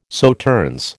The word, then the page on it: Sauternes: American English